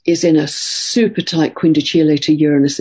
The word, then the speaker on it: quindecile: It's spelt quindecile, but it's pronounced quindicile.